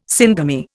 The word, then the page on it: syngamy: Indian